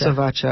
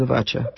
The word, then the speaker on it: Avotcja: Just Avacha. I always just use Avacha. – Spell that for us. – A-V-O-T-C-J-A.